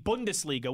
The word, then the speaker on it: Bundesliga: This Friday, so as we record this now, 3 days from now, the Bundesliga, which is the top German soccer league. – Hey, awesome job with the pronunciation, too, thank you.